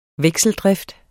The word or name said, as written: vekseldrift